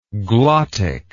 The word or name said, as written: glottic